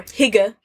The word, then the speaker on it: hygge: It's very gemütlich or hygge.